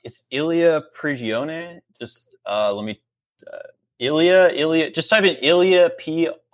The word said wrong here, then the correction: Prigogine: Oh, I think it's pronounced Prigogine. – Yeah, Prigogine. Yeah.